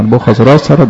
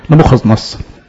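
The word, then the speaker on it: نبوخذنصر: وزي ما قلنا نبوخذ راسرة ده النطق بتاع نبوخذ نصر بس في بابل، فبيقول الاسم ده لما يخاطب المزبين في بابل، لكن لما بيخاطب الشعب الاسرائيلي بيقول النطق العبراني او الارامي نبوخذ نصر.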